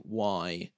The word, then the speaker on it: Rousay: And finally, if you want some ambient music, Claire Rousey, I want to say that's how you pronounce it, Rousay, is releasing a new album called Sentiment.